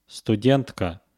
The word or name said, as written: studentka